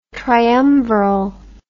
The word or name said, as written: triumviral